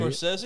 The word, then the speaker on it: Scorsese: I don't want to sound like an Scorsese? – Idiot.